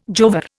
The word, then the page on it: jowar: Indian